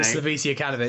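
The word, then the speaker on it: Slaviša Jokanović: Slavisa Jokanović, it's all good.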